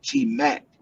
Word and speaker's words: T-Mat: Well, actually, when I first started getting the nickname, it was because of my my name was Taurus Mathis, so it was like T-Mac, like T-M-A-T. But people used to always mispronounce it and be like T-Mac, because they feel like I was, a ladies' man, so they used to be like T-Mac.